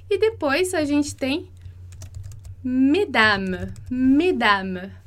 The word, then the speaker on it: mesdames: E aí, como eu falei lá, o S vai fazer abrir a pronúncia, né? Então, a gente vai falar mesdames, como a gente falou mes parents, mesdames, mesdames, que é senhoras.